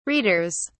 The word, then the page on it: readers: British English